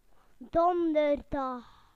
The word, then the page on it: Thursday: British English